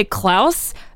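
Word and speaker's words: Clowes: It's kind of a little confusing how his name is pronounced. It's C-L-O-W-E-S, which would seem to be close, but I'm going to say Klaus, and we'll get into why in a minute.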